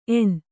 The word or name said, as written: ಎಣ್ಣೆ